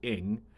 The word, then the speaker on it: Ng: So there's two ways of pronouncing it. You do it the Americanized, the English way, which is ing, or the Cantonese version, which is just which is just like sound.